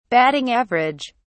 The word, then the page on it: batting average: British English